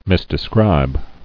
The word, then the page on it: Misdescribe: mis·de·scribe; Mis`de*scribe"